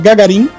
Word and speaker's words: Gagarin: Que se chama Gagarin, ou como eles pronunciam, Gagarin, uh, né, que é em homenagem ao Yuri Gagarin, Gagarin, e o Yuri também tem esse nome por conta do Gagarin.